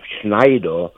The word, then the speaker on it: Schneider: Yeah, Bill Schneider, and I tried to pronounce it in German, Schneider. – Yeah. – Because it is a German name.